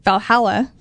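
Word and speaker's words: VA-11 Hall-A: And also coming out this week is Valhalla. It's spelled weird. – So stupid. – I'm sorry. So it's VA-11 space mm-hmm. Hall-A.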